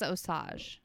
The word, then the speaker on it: Osage: I feel like I'm still saying it wrong. – I think it's Osage.